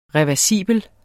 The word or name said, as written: REVERSIBEL